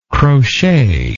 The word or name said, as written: crocheter